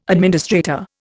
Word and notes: administrator: Indian

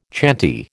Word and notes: chanty: American English

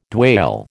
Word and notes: dwale: American English

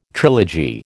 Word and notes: trilogy: American English

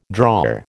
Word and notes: drawer: American English

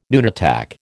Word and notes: nunatak: American English

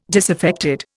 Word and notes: disaffected: Indian

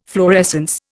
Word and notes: fluorescence: Indian

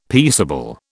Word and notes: peaceable: British English

